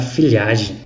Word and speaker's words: Figliaghi: É um nome, nome italiano, então se pronuncia filhagem.